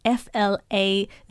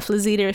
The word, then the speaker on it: Flazeda: Some people pronounce it Flazida if you're nasty. But it's, Flazeda. It's kind of like a merge of blase and laissez-faire and la-di-da all smushed together.